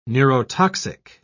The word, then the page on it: neurotoxic: British English